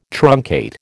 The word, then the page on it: truncate: American English